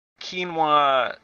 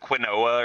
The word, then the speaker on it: quinoa: Anyway, after that was Quinoa Sushi, which it looks like it should be pronounced quinoa or something, but it's quinoa.